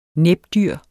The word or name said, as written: næbdyr